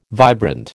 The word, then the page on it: vibrant: American English